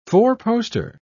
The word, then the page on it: four-poster: British English